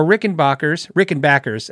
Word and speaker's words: Rickenbacker: Today I saw prices for Rickenbackers. Actually, it's pronounced Rickenbacker, if that makes sense.